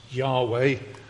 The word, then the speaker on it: Yahweh: Lord is a translation of his original name in Hebrew, which is probably pronounced Yahweh. It was such a reverent name that the Jewish scribes didn't even try to pronounce it, but the pronunciation is probably Yahweh, the Lord God.